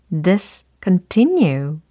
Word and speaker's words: discontinue: discontinue. 이렇게 되는 거죠. discontinue, discontinue, discontinue. 좋습니다. discontinue가 아니고 discontinue, discontinue. 이와 같이 발음을 해주시면 되겠습니다.